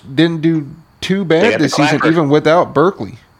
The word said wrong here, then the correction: Barkley: or that's how you say his name? – Yeah, Saquon. – Barkley. – Saquon.